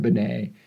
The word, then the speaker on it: Binet: And the first formal intelligence test was developed by Alfred Binet and Theodore Simon, but it's spelled Simon, S-I-M-O-N, and Binet is spelled with the silent T on the end, B-I-N-E-T.